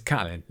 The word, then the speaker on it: Catelyn: It's Catelyn, Lady Catelyn, they say it right there.